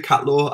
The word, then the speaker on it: Catleugh: So just Luke Catleugh. I love that you were able to pronounce that as well. So many people say my surname a whole host of different ways.